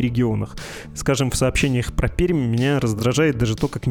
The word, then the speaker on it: Пермь: Правильно же не Пермь и не Пермь со смягчением «м». А местные говорят парадоксально. У них «м» твердое, последнее «ар» мягкий. Ну, то есть «пермь».